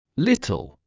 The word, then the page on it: little: British English